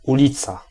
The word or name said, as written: straat